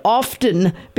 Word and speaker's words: often: When did often become acceptable? It's always been often. The T is silent.